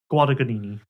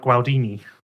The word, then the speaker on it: Guadagnini: You said there's another G in the middle. – Yeah, because in the middle, originally you just said it was like Gualdini. – No, I didn't. I said Guadagnini.